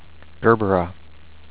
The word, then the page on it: Gerbera: GER-ber-uh